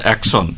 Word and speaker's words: axon: ঠিক আছে, তোমাকে নম্বরটা দিচ্ছি, ওটা অ্যাকশন, AXON। তোমাকে 10 নম্বর দেওয়া গেল। পরের প্রশ্ন, এই রাউন্ডের চতুর্থ প্রশ্ন, সোমাশ্রী, তোমার জন্য। আমরা জানি নিউরনের লম্বা লেজের মতো অংশটি হলো অ্যাকশন।